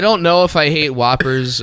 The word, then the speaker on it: Whoppers: I'll eat Whompers. I love a Whomper. – Whomper. – I said it wrong.